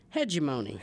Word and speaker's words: hegemony: I probably would have mispronounced it, as many do, as hegemony. – Hegemony, yeah. – Right. – Mm-hmm. Right. – Now, can you guess what the memory trick is that I use to remember that it's pronounced hegemony? – No.